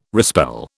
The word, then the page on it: respell: British English